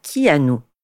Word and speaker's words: Keanu: Parce que oui, Keanu, ça se prononce en réalité Keanu.